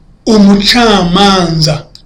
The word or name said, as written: umucamanza